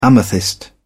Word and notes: amethyst: British English

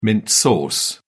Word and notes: mint sauce: British English

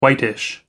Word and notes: whitish: American English

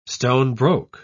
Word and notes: stone-broke: British English

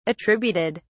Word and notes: attributed: British English

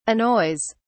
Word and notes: annoys: British English

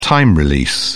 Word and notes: time-release: British English

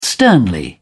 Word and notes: sternly: British English

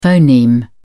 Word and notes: phoneme: British English

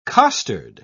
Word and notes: costard: British English

